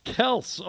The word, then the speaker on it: Kelce: Kelce. Oh, that's right. He changed his pronunciation.